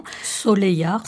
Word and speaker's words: Soleillard: Soleillard, si je me trompe. – Exactement, bravo. Avec en occitan, c'est comme ça que ça se prononce, tout à fait.